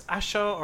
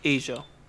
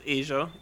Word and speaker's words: Asa: I don't know if it's Asha or Asia, whatever, mm-hmm, it is. – Asia. – It's actually pronounced like that? – It's pronounced Asia. It is spelled A-S-A.